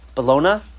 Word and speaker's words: Bologna: That's how you pronounce it, right? Bologna? Not, yeah. Bologna? Okay, Bologna.